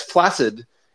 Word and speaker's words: flaccid: HBO Silicon Valley, where Richard Hendricks, where Ehrlich insults a venture capitalist by describing his genitalia as flaccid, and Richard Hendricks points out, actually, it's pronounced flaccid. Most people don't know that.